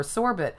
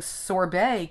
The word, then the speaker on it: sorbet: But we pronounce it more often the French way, the sorbet way, whereas in British English, it's pronounced sorbet more often, which I kind of like.